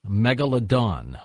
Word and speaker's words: megalodon: What's it, how's it pronounced? – Megalodon.